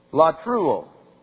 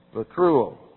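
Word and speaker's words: Latruo (λατρεύω): Latruo. L-A-T-R, Greek letters, E-U, long O. Latruo.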